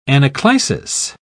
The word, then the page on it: anaclisis: British English